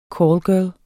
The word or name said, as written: callgirl